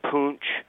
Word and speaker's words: Punch: And I don't know if you pronounce it punch or punch. – Punch. – Is it punch? – Yes, sir.